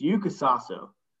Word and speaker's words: Yuka Saso: I want to apologize real quick because last week I said I didn't know how to pronounce the first name of the women's open champ, U.S. Women's Open champ. She goes by Yuka. It's Yuka Saso.